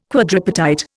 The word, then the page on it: quadripartite: Indian